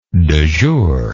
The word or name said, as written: du jour